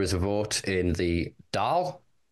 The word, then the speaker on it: Dáil: So the I-F-A-D-A kind of gives it, but the A-I-F-A-D-A, yeah, gives it an A sound, like, you know, so Dáil. It's kind of like y'all, but Dáil.